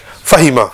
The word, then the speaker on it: Fahima: So the way to pronounce it is quick. Fahima. – Fahima.